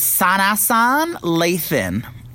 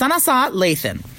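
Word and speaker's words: Sanaa Lathan: Yeah, so the girl's name is Sanasan Lathan. It's pronounced Sanasa Lathan.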